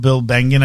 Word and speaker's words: Bengen: So Bill Bengen, I believe is how you pronounce his name, is the father of the 4% rule, right? Is that how I remember pronouncing his name properly? – Mm-hmm.